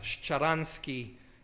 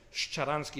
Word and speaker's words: Sharansky: And this is how they pronounce sh. Like Sharansky is Sharansky